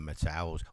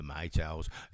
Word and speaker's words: Maytals: Anyway, let's carry on with the Metals, or the Maytals, if you want to say it right.